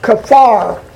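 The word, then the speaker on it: kaphar: Now, I may not pronounce this right, but it's pronounced kafar. That's K-A-P-H-A-R, the Hebrew word for pitch.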